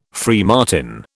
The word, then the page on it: freemartin: British English